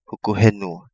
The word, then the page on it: Hukuhenua: Kapingamarangi